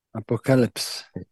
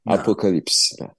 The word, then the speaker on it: Apocalypse: Nasıl deniyordu? Apokalips. Apokalips. Evet peki.